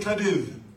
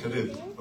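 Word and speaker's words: Cádiz: But you got to say it like you got a lisp. That's how they pronounce it. Say Cadiz. – Cadiz. – Okay, very good. If you come from Latin America, you don't do the lisp thing, but they do it in Spain.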